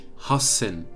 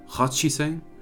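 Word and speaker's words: Hassen (八千): 8000 ist Hassen, nicht Hachiseng sondern Hasseng.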